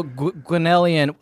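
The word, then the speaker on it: Gwenllian: And then so Gwenellian, I'm the one who can't pronounce it. I'm the one who's supposed to be able to pronounce it. – Gwen. There's a sneaky TH in there.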